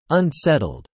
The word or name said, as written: unsettled